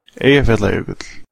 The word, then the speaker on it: Eyjafjallajökull: Eyjafjallajökull.